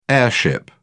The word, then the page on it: heirship: British English